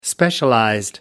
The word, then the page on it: specialized: American English